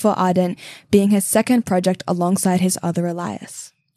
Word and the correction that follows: alias: Alias. That's the first time I've ever heard that word pronounced that way. But I guess phonetically, you're not too far phonetically from that pronunciation.